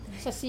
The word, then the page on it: sasi: Wamesa